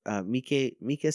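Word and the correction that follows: Miike: Snow. It's M-I-I-K-E, Mike Snow.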